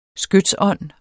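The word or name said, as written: skytsånd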